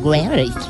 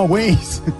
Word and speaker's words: Waze: Es muy fácil de pronunciar, Waze. No, Waze.